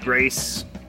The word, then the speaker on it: Grais: I think it's pronounced Grace. G-R-A-I-S.